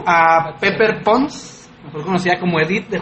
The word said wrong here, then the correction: Pepper Potts: Ah, Pepper Potts.